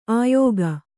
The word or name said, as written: ಆಯುಗ